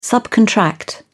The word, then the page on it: subcontract: British English